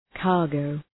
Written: cargo

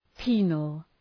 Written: penal

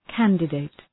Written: candidate

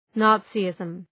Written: Nazism

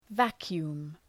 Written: vacuum

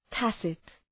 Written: tacit

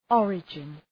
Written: origin

